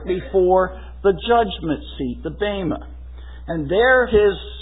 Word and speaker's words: bema: The usual English pronunciation is bema, B-E-M-A. But the Greek is literally bema.